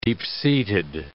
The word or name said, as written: deep-seated